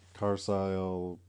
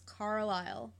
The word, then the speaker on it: Carlisle: Carlisle, Pennsylvania. – How is it spelled? – Carlisle. – You're saying it wrong. It's Carlisle.